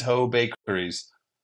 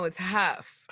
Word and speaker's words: Hough: I would pronounce, if I just saw that, I would pronounce it Ho. – No, it's Huff.